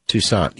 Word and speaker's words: Toussaint: And Alan Toussaint? – Toussaint, yeah. – Toussaint. Mm-hmm. Ah, close enough.